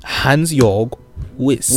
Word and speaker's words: Hans-Jörg Wyss: There's a man by the name of Hans-Jörg Wyss. – Who? – Hans-Jörg Wyss. – Why you gotta say it like that? – Because he's foreign. – Okay. – You gotta pronounce his name properly.